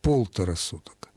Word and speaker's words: полтора: И вторая рекомендация – использовать в этом случае особую форму числительного полтора, изменяя акцент, ставя ударение на первый слог – полтора суток.